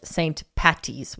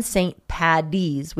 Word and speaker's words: St. Paddy's: As an Irish person, please, please don't call it St. Patty's with T's. That's something only Americans say. – Yeah. – Even St. Paddy's with D's is acceptable.